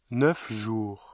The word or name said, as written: neuf jours